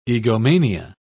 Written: egomania

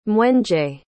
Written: mwenje

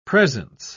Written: presence

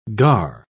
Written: gare

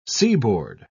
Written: seaboard

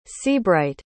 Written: seabright

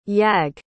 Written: yag

